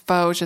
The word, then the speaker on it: Vosges: It's Vosges.